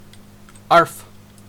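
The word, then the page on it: arf: Canadian English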